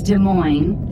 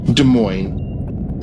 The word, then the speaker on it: Des Moines: Is it Des Moines? Is it Des Moines? You'd be the one to ask on that. – I think you said it right. I think it's Des Moines. – Des Moines.